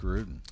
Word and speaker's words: Gruden: Is it John Gruden? I don't want to pronounce his name wrong. – John Gruden.